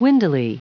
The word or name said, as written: windily